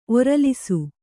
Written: ಒರಲಿಸು

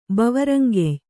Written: ಬವರಂಗೆಯ್

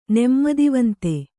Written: ನೆಮ್ಮದಿವಂತ

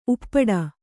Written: ಉಪ್ಪಟ್ಟ

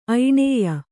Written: ಐಣೇಯ

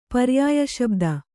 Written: ಪರ್ಯಾಯಶಬ್ದ

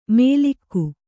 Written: ಮೇಲಿಕ್ಕು

